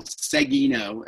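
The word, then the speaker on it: Seguinot: That's S-E-G-U-I-N-O-T. You can Google me or look me up on LinkedIn. But in the professional world, Seguino is hard to say or hard to pronounce and even harder to spell.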